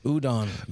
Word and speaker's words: udon: Udon. You've never heard of udon noodles? – No. – Really? – No. – U-D-O-N, very popular.